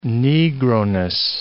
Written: Negroness

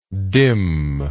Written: dimness

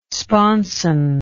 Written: sponson

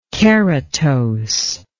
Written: keratose

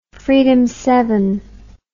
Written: freedom rider

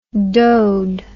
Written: dode

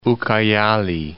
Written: Ucayali

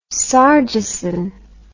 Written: sargeson

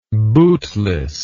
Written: bootlessness

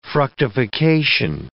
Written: fructification

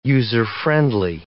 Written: user-friendly

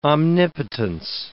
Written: omnipotence

